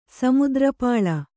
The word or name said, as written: ಸಮುದ್ರಪಾಳ